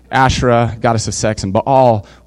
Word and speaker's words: Baal: And then later in verse 16, we see that they were worshiping Baal too. I know we all say Baal, but it's Baal. Like that's how you say it.